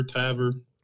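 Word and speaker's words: Tavour: My dad started getting like boxes from Tarvor or Tavor, however that's pronounced. – Beaver, Tavor. – Right. Yeah, Tavor. Yeah, I've heard it many times.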